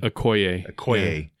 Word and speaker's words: Okoye: And it was heartbreaking when she, you know, fired a Coulier. Is that how you say her name? The main Okoye.